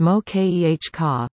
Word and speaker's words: moqueca: That fact is undemonstrated in today's recipe for moqueca, pronounced mo-k-e-h-ka, a Brazilian-style fish stew.